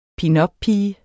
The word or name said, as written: pinuppige